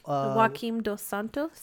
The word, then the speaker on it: Joaquin Dos Santos: Okay, so how do you say the director's name? – Joaquin Dos Santos? – Yes.